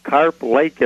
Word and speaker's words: Carp Lake: Talking next, who's calling from Carplight, Michigan. Am I saying that right? You're on the air with Bible Answers Live. – Yeah. Carp Lake is a fish. – Oh, okay. – The fish, carp.